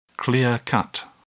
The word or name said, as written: clear-cut